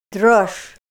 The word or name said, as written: drosh